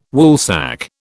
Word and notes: Woolsack: British English

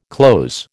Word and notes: cloze: American English

